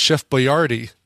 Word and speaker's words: Boyardee: Because we discovered his name is pronounced Chef Boyardee.